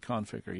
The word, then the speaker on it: Conficker: Is it Conficker? – Most people pronounce it Conficker, yeah.